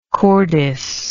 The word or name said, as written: cordis